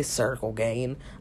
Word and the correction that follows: Ciryl Gane: I meant Ciryl Gane. What I see, like so, I, sometimes I just mispronounce names on accident. So no, I know how to say his name. But anyway, Ciryl Gane I'm a huge fan of Ciryl Gane.